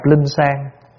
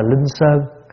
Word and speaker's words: linh sơn: Chỉ tu nhất hướng nhập linh sang, thật ra là linh sơn. Nhưng mà tại vì chữ sơn có hai cách đọc, một là đọc sơn, hai là đọc sang. Và để cho nó âm nó vần với cái chữ sàng nhang.